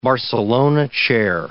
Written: Barcelona chair

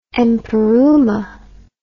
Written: empyreumatic

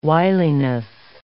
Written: wiliness

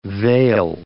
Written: vale

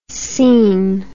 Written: cene

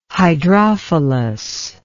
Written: hydrophilous

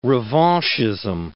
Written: revanchism

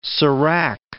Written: serac

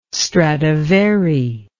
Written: stradivari

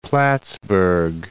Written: Plattsburgh

